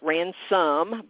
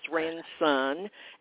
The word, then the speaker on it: Ranson: So my website is my name, Anne Ranson. That's all N's as in Nancy. Most people want to spell it Ransom, but it's Ranson, and I have no E on the end of Anne.